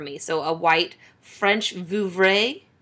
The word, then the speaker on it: Vouvray: French Vouvray is correct.